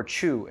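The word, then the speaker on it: qu: what they call koji in Japan or chu in China. – Okay, and so chu, and that's spelled Q-U with a little...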